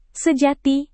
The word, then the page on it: sejati: Indonesian